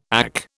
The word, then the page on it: AC: British English